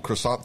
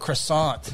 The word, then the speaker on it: croissant: The little croissant thingies. Little cherry in the middle. Got an apple, a cherry, apricot. – It's pronounced croissant.